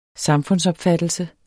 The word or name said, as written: samfundsopfattelse